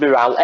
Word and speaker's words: Miral: I think it's pronounced Morale, M-I-R-A-L, and that's Tom and Bolana's daughter.